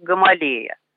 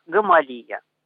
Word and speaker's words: Гамалея: Но по радио, насколько помню, до последнего его все-таки называли Гамалия. И мне это более привычно, поэтому в наших двух программах я буду произносить его фамилию как Гамалия.